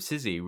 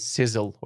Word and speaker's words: Sizzy: Once I paid for an ad for it and it cost a lot of money and the host pronounced it as sizzle or something and we couldn't undo it.